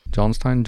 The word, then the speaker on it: Johnstown: Johnston Castle Wexford, am I pronouncing that rightly? Johnstown, Johnston? – Johnstown, I would say. Johnstown Castle in Wexford.